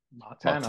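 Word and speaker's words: Martín: How do you pronounce the Spanish Martin? – Martin. I would say, yeah.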